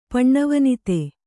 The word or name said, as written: ಪಣ್ಣವನಿತೆ